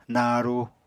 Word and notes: naru: Apatani

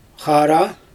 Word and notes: xaara: Xyzyl

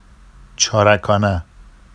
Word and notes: ʧɑrakɑna: Vafsi

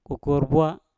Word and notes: kokour bwa: Mpur

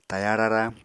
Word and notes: taya rara: Apatani

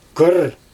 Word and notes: kyr: Xyzyl

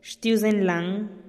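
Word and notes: xtiuzen lan: Teotitlán del Valle Zapotec

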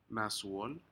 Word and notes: nasuol: Aneityum

